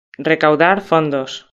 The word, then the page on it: recaudar fondos: Spain Spanish